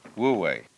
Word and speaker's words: Wu Wei: So the transitional word, which shows the way to realize De in one's life, and then with this character, this is pronounced, this one is Wu, and this one is Wei, Wu Wei.